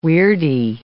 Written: weirdie

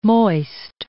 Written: moist

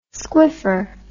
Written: squiffer

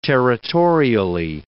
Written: territorially

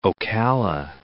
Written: Ocala

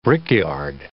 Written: brickyard

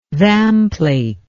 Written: vamplate

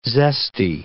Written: zesty

